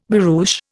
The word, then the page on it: barouche: Indian